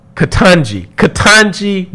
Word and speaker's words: Ketanji: Jaconi, I just messed up her name, and I cannot think of the correct pronunciation right now, but Supreme Court Justice Brown Jackson, Ketanji Brown Jackson.